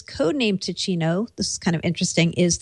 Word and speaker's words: Ticino: It's Ticino, maybe? T-I-C. I-N-O. – I think if it's Italian, it would be Ticino.